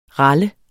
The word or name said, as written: ral